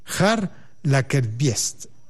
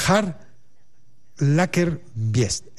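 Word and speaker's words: Pär Lagerkvist: Mi sueco no es muy bueno, voy a tratar de decirlo como se pronuncia en sueco. Su nombre es Har Lagerkvist. Ese es el nombre. Se escribe P, una A con las viguelas o con los puntitos arriba, y la R. Pero se pronuncia Har Lagerkvist.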